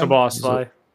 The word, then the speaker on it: Szoboszlai: Szoboszlai. – Szoboszlai for $70 million, which was quite the purchase, but we'll see.